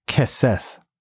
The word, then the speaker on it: keseth: The word pillows here in your Strong's Concordance is H3704, and it's pronounced in the Hebrew, keseth, and it means a cushion or pillow covering a seat or bed.